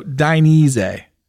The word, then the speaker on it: Dainese: So, Dainese. – So we're going to go out to dinner and dine. Dine? – No, more like die. Like you're dyeing the leather.